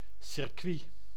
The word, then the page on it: circuit: /sɪrˈkʋi/